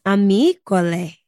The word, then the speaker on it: Amicole: So it's pronounced Amicole.